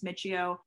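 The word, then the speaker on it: Miccio: So I'm at Brooke Michio, M-I-C-C-I-O. I know people are like, how do I pronounce your last name? Like, there's a lot of pronunciations. I won't get mad, but it's Michio on Twitter, Instagram.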